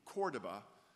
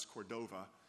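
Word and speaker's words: Córdoba: And we came in Cordoba, which we might pronounce Cordova, in Spain, to a interesting building that at present is a mosque with a cathedral built in it.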